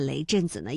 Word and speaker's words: 雷震子: 那之前呢，我们也在节目当中梳理过啊，类似于像孔子、孟子，这个子呢虽然没有实意，但是要保持声调来凸显人名的完整性。那雷震子呢也是如此哈。